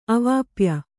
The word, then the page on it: ಅವಾಪ್ಯ: kannada